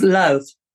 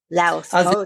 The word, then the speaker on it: Louth: And did I pronounce that correctly? – It's Louth. – Louth. – As in South. – Louth.